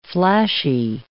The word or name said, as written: flashy